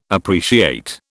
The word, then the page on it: appreciate: British English